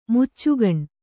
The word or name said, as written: ಮುಚ್ಚುಗೊನೆ